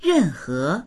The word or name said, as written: ren4he2